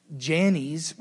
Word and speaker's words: Jannes: Verse 8 says these two men's names, and I looked up how to pronounce them so I didn't sound like an idiot. Jannes, which I feel bad that his mom named him that, and Jambres, same thing, opposed Moses.